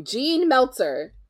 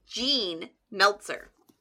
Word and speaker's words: Jean Meltzer: Dude, you asked me how to pronounce it before we started recording, and you said it correctly. While we're filming, you say it wrong. Jean Meltzer. – There's a lot wrong with me, guys.